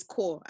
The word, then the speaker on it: corps: I think it's apparently it's core. Apparently, it's that the PS is silent because you know how they say core.